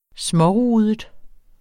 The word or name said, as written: smårudet